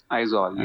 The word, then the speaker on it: Aizawl: And I think you'll understand why, but Aizwal, that's going to be my team. Is that how you pronounce it as well? – Aizawl, yeah.